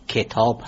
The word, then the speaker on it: کتاب: به طور مثال تلفظ درست واژه کتاب، کتاب هست، یعنی با کسره کاف تلفظ میشه نه با فتح.